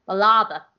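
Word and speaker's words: Billaba: During the battle, Kanan, then known as Padawan Caleb Doom, fights alongside his master, Depa Bialba. Balaba. I always mis-say her name. Balaba. I always want to say Bialba. It's not. It's Balaba.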